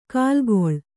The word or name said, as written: ಕಲ್ಲಿಗೂಳು